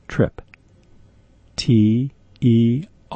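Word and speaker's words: Tripterygium: let me spell it for you. Get a pencil. T-R-I-P. Trip. T-E-R-G-I-U-M. Tergium. Trip tergium. Second word, will, W-I-L, fordi, F-O-R-D-I-I.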